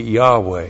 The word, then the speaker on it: Yahweh: If you pronounce that, it would be Yahweh.